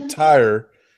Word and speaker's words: Tyor: Uh, the three kingdoms have been overtaken by three evil lords, and only uh, Tyre, T Y O R, is how they pr- pronounce it.